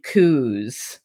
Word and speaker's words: coo: It's spelled C O O. It's coo my Scottish people told me.